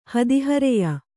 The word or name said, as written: ಹದಿಹರೆಯ